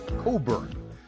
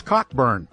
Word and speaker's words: Cockburn: His name was Bruce Cockburn, spelled C-O-C-K-B-U-R-N. Cockburn. But of course, I would intentionally mispronounce it to Dustin's annoyance. I'd say, oh, that's a great song, Dustin, by Bruce Cockburn. And he'd say, it's Cockburn. It's Cockburn. And I say, hey, Dustin, did you just hear that?